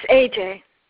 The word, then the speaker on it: Ece: Is it Ece? E-C-E? – It's AJ. That's all AJ.